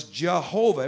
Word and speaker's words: Jehovah: Of course, another pronunciation for Yahweh early, many, many years ago was Jehovah. Everybody say Jehovah. And that is, of course, a, a, a pronunciation. It's not accurate according to the Hebrew, but it is a, a way to pronounce the name of God in English by putting a J sound at the beginning.